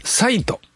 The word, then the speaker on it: XIT: チャット GPT を活用してテレビの字幕を様々な国の言葉に自動翻訳するスマートテレビ向けアプリの XIT と書いてサイトと読むんですが発表しています。